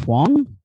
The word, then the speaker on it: Huang: I think it's pronounced Huang. Wing Eddie Huang. – Yeah.